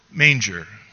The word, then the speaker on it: manger: In French, M-A-N-G-E-R is pronounced manger, and it is the French verb for to eat.